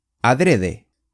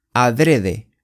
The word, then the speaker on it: adrede: 今天的句子是 adrede, adrede,